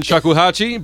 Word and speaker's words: Shakuhachi: Duo for Koto and Shagohashi. I mispronounced it before. I named a city in Japan.